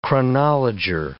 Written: chronologer

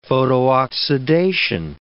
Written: photooxidation

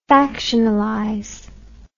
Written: factionalize

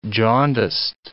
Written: jaundiced

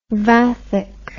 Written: Vathek

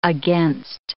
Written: against

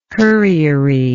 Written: curriery

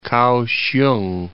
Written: Kao-hsiung

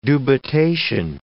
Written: dubitation

